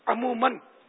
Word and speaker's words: عموماً: سائل کو بھی تلفظ سکھانے کی ضرورت ہے. عموماً عین پر پیش ہے.